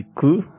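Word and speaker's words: ku (九): Por cómo se pronuncia, ¿no? Exacto. Shi, suena como muerte. – Y ku, de kurushi, de sufrimiento.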